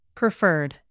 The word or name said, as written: preferred